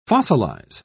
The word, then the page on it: fossilise: British English